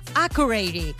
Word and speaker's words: Accurate: Accurate. Y lo he pronunciado así porque así lo ha dejado claro Aitana en el chat que tiene en Instagram, donde ha matizado la pronunciación de su nueva canción, aunque a ella también tuvieron que dejárselo claro cuando estaba en Islandia.